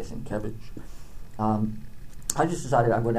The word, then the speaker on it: Sienkiewicz: It's pronounced Sienkiewicz, accent on the second syllable.